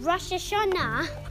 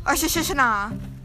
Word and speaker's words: Rosh Hashanah: Rosh Hashanah.